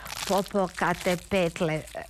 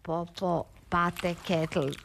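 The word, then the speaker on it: Popocatépetl: Reci popokate petle. Reci popokate petle. Ona jadnica uporno govorila popopate ketl. Rekao je, glupača si, ne znaš izgovoriti ni popokate petle, a osim svega imaš ove mizerno male grudi.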